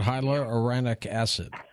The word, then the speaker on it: hyaluronic acid: What about hyaluronic acid? – Say that again? – No. – Yo, you know how to say it. Hi-Al. Remember Hi-Al? – Hyaluronic acid. – Yeah, there you go.